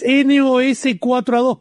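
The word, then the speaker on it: NOS4A2: Sí, eso que no puedo pronunciar es Nosferatu, pero escrito con números. Es basado en un libro de hijo de Stephen King. – ¿Mirá? Nosferatu. Claro. – Mirá. – Es NOS-4A2, porque es la chapa del auto del villano que es...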